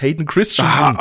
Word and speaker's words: Hayden Christensen: Ja, hier äh, einmal, weil ich mich die ganze Zeit gefragt habe, irgendwas an der Aus Aussprache von, von äh, Anakin beziehungsweise von, meinem, von seinem Schauspieler bei dir klingt falsch. Er heißt Hayden Christensen.